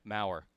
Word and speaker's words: Maurer: Is his last name pronounced differently than it's spelled? – Yes. – Yeah, Maurer.